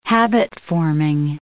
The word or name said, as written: habit-forming